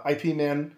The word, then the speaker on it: Ip Man: Uh, Donnie Yen, and, he's in uh, Ip Man. – Yeah. I think it's Ip, Ip, Man is how they pronounce it.